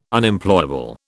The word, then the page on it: Unemployable: British English